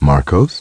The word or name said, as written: Marcos